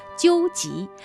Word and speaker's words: 蟾蜍: 不远处，小创意招财纠集品牌展区响起了一阵欢呼，原来是有观众抽中了一只憨态可掬的西河金招财三角蟾蜍。该品牌业务经理徐若欣介绍说，蟾蜍在闽南话当中呢，读音就读作纠集，三角蟾蜍则寓意招财进宝。